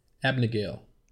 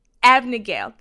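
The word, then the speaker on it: Abagnale: Abnegale. – Abnegale.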